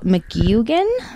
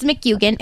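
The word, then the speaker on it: McGuigan: I think it says McGugan. – Let's Google it. – Okay. Well, it's McEugan.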